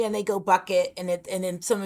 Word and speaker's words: Bucket: And that's a thing, guys listening, that literally her name is spelled, she'll spell it B U C K E T, bucket. And they'll go, the person on the other phone will go, she goes, no, it's bouquet. There's no way in the world that that is spelled bouquet.